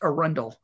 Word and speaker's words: Arundel: And if you listen to the song Arundel, I think it's pronounced Arundel, like Anne Arundel in the DC area. If it's Arundel, I'm sorry, but I don't know. I've only ever heard that word pronounced on the DC Metro, so I'll say Arundel.